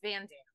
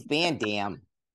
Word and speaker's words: Van Damme: It's Van Damme. – You can't even speak English. You're telling me how to say it's Van Damme.